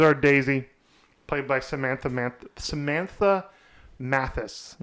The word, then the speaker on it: Mathis: Mathis. That's how you say it.